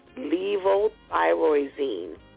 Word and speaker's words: Levothyroxine: Well, many people are prescribed Now I'm going to spell it for you. Ready? Levo, L-E-V-O, Thyro, T-H-Y-R-O, Zine, X-I-N-E. Levothyroxine, okay? Or other thyroid medications to help balance out the hormones.